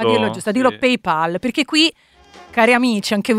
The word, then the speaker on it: PayPal: Gente che dice PayPal. – No, non si dice PayPal. Non è Paul. – Non è Paul Newman. – No, non è Paul McCartney. – È Pal come amico.